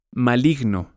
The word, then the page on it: maligno: Spanish